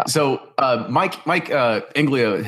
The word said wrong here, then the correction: Inglima: has said, Inglima. I'm really bad at pronouncing names tonight. But anyway, Inglima. That's the other Superfly guy.